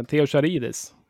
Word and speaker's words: Theo Charidis: Yes, eh, jag vet inte exakt hur man uttalar namnet, men eh, Theo Charidis. Det, det? Ja, exakt. Jag, jag, tror att uttalet var perfekt.